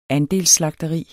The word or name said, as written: andelsslagteri